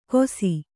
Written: ಗೋಸಿ

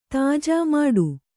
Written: ತಾಜಾ ಮಾಡು

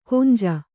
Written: ಕೊಂಜ

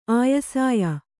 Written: ಆಯಸಾಯ

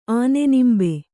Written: ಆನೆನಿಂಬೆ

